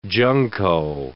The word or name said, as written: junco